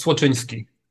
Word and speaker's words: Słocyński: And last name is super close. It's Słocyński.